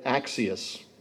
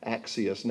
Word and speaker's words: axios: Now, this is all Greek to me, so recognize that I'm using the transliteration to pronounce it. It may or may not be exactly right, but based on the emphasis given to the syllables in this particular word in Strong's, it's axios, or deserving of praise, deserving, comparable, or suitable, as if drawing praise or due reward.